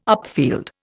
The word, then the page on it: upfield: Indian